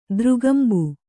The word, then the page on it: ದೃಗಂಬು: kannada